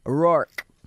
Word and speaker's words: Rourke: I wanted to see if you guys could give a big happy 30th birthday shout out to my wonderful friend, Rourke. Pronounced like a lion's roar plus K. Rourke.